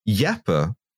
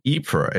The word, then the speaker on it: Ypres: I went to Yeppe, or Ypres if you prefer, or Wipers if you want the British pronunciation, and it was a horrible place.